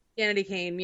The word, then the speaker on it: Danity Kane: Am I saying that right? Danity Kane. – Danity Kane. Yeah.